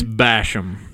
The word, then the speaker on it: Basham: It's Basham. – Like Basham? – Yeah. – Like you bash it? – Yeah, like you're beating somebody up. – Like you're bashing them. – Yes. You have to say it like a redneck.